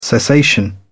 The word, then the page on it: cessation: British English